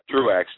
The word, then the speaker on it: Truax: Is it Trax? Am I mispronouncing it? Truax, just like it's spelled.